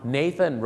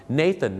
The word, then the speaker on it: Nathan: Nathan Road นั่นเองนะครับซึ่งชื่อฝรั่งนะครับเดี๋ยวถ้าผมมีโอกาสครั้งหน้าเราจะดูนะครับว่าชื่อฝรั่งนะครับหลายๆชื่อที่เราออกเสียงผิดมีอะไรกันบ้างแล้วฝากไว้หนึ่งชื่อครับ Nathan นะครับไม่ใช่นาธานครับ